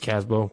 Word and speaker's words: Kasbo: I don't know how you really pronounce it. I think it's Casbo. KASBO, right? – Yeah.